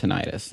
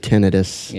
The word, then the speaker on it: tinnitus: So when you're hearing a sound that has no external source, that's called tinnitus. That's usually ringing. – You can pronounce it tinnitus.